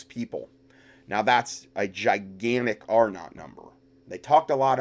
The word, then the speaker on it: R-naught: It's R0, but it's pronounced R-naught.